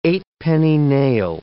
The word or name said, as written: eightpenny nail